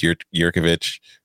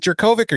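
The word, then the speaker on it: Jurkovec: They're a much better team with Yurkovich. Or is it Jurkovic or Yurkovich? Because I know a person whose last name is spelled the same way and it's pronounced Yurkovich, but everybody pronounces it Jurkovic.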